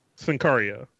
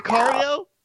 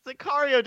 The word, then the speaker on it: Sicario: Sincario. – What? – Chicago. – No, is that Sicario? – Bryce, you want to fix this for him? – Chicago. – You win! – Chicago. – Sicario did not win an Oscar.